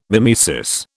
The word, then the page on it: mimesis: British English